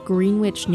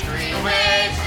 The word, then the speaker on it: Greenwich: We took you back to the small town of Greenwich, New York, where he was allegedly last seen. – It's Greenwich, not Greenwich. Say it phonetically.